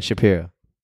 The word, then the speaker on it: Shapiro: Ben Shapiro? – Shapiro? – Yeah, Ben Shapiro.